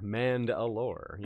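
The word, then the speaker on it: Mand'alor: Wheel of Time fans might hear this name as a familiar sound because it it rhymes with Rand al'Thor, Mand Alor.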